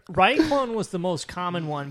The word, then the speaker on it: Rei Clone: I also am glad you guys said your name, because even though I was confident it's Rei Clone, it's spelled R-E-I, and then clone is spelled clone. I was worried. – Can you spell it anyway? – People really struggle with that name, especially when we were on tour. We got REI clone, Rei Clone, just anything but how it's pronounced.